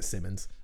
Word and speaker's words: Simmons: Yeah, that might be either the Simmons, Simons, however you say it. Or I pronounce it Simmons.